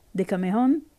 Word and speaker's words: Decameron: Decameron, Decamerone, Decameron. – E insisterei su Decameron, con l'accento sulla ultima O, come tutte le parole di origine greca nell'italiano antico, quindi meglio sarebbe Decameron o Decamerone, scegliamo una delle due.